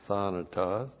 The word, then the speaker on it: thanatos: This is the preposition pros, P-R-O-S. This is the noun thanatos, T-H-A-N-A-T-O-S. Now, the negative me is no or not. The preposition pros literally means face to face with. Face to face with. And thanatos, that's the noun death.